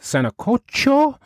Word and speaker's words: sancocho: Pronounced sancocho,